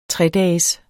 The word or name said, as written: tredages